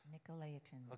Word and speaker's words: Nicolaitans: Nicolaitans.